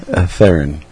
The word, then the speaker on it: Theron: How do you pronounce the last name? – Theron. – Yeah, I thought it was Theron.